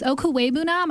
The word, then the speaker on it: Okwuegbunam: Okwuegbunam, I know.